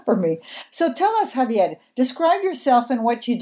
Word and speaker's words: Javier: I'm your host, and I am very pleased to interview Javier Bahir. How do you say in English? Javier Bahir? – That's perfect, actually. You said it in Spanish.